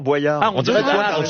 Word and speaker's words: Boyard: Non, Boyard. On devrait dire Boyard. – J'avais raison. Boyard comme boyau, voilà.